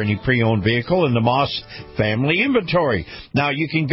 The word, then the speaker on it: Maus: Now, Moss is spelled M-A-U-S, and